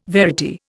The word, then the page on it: verity: Indian